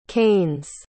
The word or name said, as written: canes